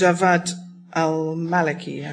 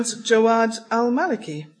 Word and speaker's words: Jawad al-Maliki: There's the proper pronunciations. I'll see if that makes a difference. – Okay, Qiu Xiaohua. – You're quite right. – Well, nearly. Olusegun Obasanjo, well, obviously. That's fine. Obviously, absolutely. And Jawad al-Maliki.